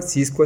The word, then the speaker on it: Xisqua: Bueno, nuestras redes sociales nos pueden encontrar en Instagram como Huerta Nueva Siscua. Siscua se escribe Xisqua, ¿sí?